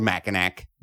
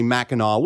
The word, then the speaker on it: Mackinac: It's spelled Mackinac. But it's pronounced apparently Mackinac, which I think is really on you, Michigan. – You think? – More than it is on me. You think? – But although I was pronouncing it Mackinac, it's actually pronounced Mackinac Island.